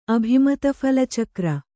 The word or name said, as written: ಅಭಿಮತಫಲಚಕ್ರ